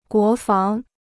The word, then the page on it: 国防: guó fáng